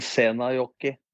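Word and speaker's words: Seinäjoki: Karrer, Europa, første kampen nå, Glimt-Lindfield, LSK skal ut mot Seinjonen, FK, rett gjerne på meg hvis dere uttaler det andre. Seinjonen. – Senajoki. – Senajoki FK, og så skal Viking ut mot Sparta Praha, og så skal Molde ut mot Elfsborg.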